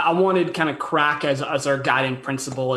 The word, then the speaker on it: craic: So they're like, oh, but it's pronounced crack.